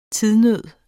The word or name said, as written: tidsnød